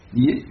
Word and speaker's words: nier: Mais je vous dis, alors, dans le cours du XVIe, XVIIe siècle, alors, prononciation actuelle qui s'installe, nier une seule syllabe.